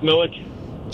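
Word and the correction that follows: Miculek: Jerry Michalik.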